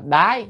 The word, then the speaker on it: đới: Bàn cập, à, thì bàn chữ cập là và, chữ này hay âm là đới, đới nghiệp đó, đới nghiệp bản sanh đó. À, tra từ điển thì nó âm là đái, nhưng mà âm đái không hay nên phải đọc là đới ha, đọc chạy âm đi.